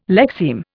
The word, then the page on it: lexeme: Indian